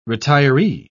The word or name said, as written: retiree